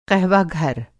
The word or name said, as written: क़हवाघर